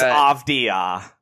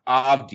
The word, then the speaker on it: Avdija: I'm not even saying this right. – It's Avdija. – Avdija. Yeah. Denny Avdija? – Okay. Yeah, Avdija, I think.